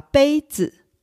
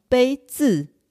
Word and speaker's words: 杯子: 例如，把杯子说成杯字或杯兹的话，别人是听不太懂的。